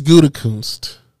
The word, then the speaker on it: Gutekunst: Brian Willis Gutekis. How you say his name? – Did you say Willis? – That's his name. – Is that his middle name? – Yeah. Big Frenchie just don't make up names. – Wow. It's Gutekunst.